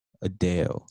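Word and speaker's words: Addeo: Adeo. – Like, yeah, you can find her on Instagram at Sam Adeo. It's A-D-D-E-O-O.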